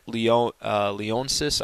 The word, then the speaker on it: Leonsis: Called the owner Ted Leonsis, I think is the way you pronounce that.